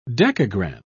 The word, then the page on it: dekagram: British English